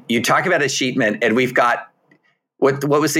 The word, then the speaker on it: escheatment: but I typically hear the word escheatment pronounced with a real punch on the middle syllable.